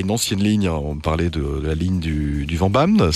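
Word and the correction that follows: Vennbahn: c'est ça? – De la Fennbahn, oui, tout à fait. – Ouais, pardon, ça prononce comme ça.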